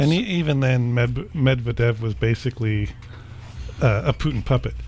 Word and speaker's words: Medvedev: No, Medvedev.